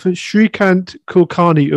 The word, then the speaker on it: Shreekant Kulkarni: Hi, welcome to another episode of Kastoshi, and today we have Shreekant Kulkarni of Green Hashes.